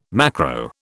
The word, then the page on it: macro: British English